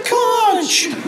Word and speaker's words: conch: Because you spelled it C-O-N-K. – Conk. – Then I learned is it conk? – In the movie, I guess I shouldn't have learned the pronunciation of the movie. The little kid who plays Piggy yelled, I've got the conch!